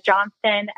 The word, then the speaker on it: Johnston: My name is Sophia Johnston, S-O-P-H-I-A, J-O-H-N-S-T-O-N.